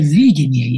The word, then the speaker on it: видение: Ведь что такое видение, я подчеркиваю ударение на первом слоге, это феномен очень особенный.